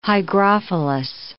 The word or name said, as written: hygrophilous